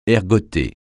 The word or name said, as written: ergoter